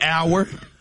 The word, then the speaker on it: hour: This was another one that threw me hour. – Like time. – Yeah. – The H is silent. – Exactly. H-O-U-R, the H is silent. Doubt. – But wait, I'm sorry. Mm-hmm. O-U-R is also hour.